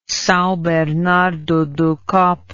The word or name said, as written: Sao Bernardo do Campo